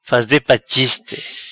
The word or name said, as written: Faze pa chiste